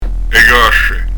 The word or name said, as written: casce